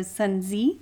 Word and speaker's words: Sun Tzu: Um, I was just listening to a video that talked about that the actual pronunciation doesn't use the U for Sun Tzu. It's Sun Z like T Z together, so Sun Tzu.